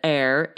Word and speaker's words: heir: Okay, so that last one he said, heir, H-E-I-R, is really the type of word that breaks pronunciation norms. When you see it, you might want to pronounce that H, right? But you don't. Right. It's silent.